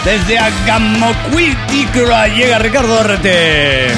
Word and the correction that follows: Angamacutiro: Esto es imposible de pronunciar. Angamacutiro.